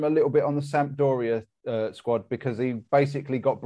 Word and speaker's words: Sampdoria: And was at Sampdoria.